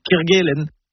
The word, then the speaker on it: Kerguelen: Eh bien, si on le fait pour Gwen, autant bien le faire pour tout, Kerguelen. On connaît tous les îles Kerguelen. Alors pourquoi on entend parfois parler de Ké Kerguelen, comme avec un père par exemple? Non, c'est Ké Kerguelen.